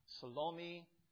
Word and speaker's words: Salome: And then lastly here in this group of women was a woman named Salome, S-A-L-O-M-E. Sometimes I've heard it pronounced Salome. It just sounds too close to salami for me. And don't want to do that. So we'll call her Salome.